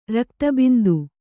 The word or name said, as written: ರಕ್ತಬಿಂದು